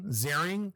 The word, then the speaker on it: Ziering: Ian, or I just found out it's pronounced Ian Ziering.